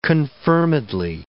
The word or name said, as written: confirmedly